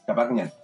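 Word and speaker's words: Qhapaq Ñan: porque la red de caminos que conformaban el Capacñán, sí, lo dije bien, Capacñán,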